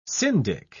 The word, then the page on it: syndic: British English